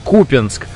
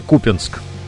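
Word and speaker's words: Купянск: Сегодня в полночь диктор в СМИ читал новости и говорил про дела на фронте, говорил Купинск. А как правильно ударение? Или какой-то профессионализм? Никакого профессионализма, правильное ударение, Купинск.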